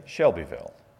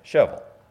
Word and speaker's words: Shelbyville: And if you're from Shelbyville, Tennessee, you don't say Shelbyville. You say Shovel. And everybody knows that's how you say it.